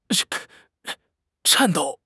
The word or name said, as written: 颤抖